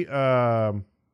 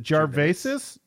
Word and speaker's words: Gervais: It's a lot like hiring Ricky Gervais. How do you say his last name? Is it Gervaisis? – Gervais. – Gervais.